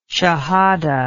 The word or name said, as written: shahada